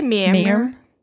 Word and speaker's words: Mamre: I'd say Mamre. – Mamre.